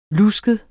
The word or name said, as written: lusket